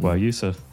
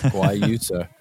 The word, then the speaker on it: Guayusa: Guayusa. – Guayusa.